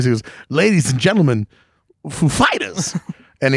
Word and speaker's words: Foo Fighters: how does the pronunciation inflection happen on the Foo or the Fighters, and Dave Grohl knows that Chris Walken has that way about him, so he says it's on the Fighters.